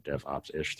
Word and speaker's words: DevOps’ish: I run a newsletter called DevOps’ish, ISH, so DevOps’ish, three syllables.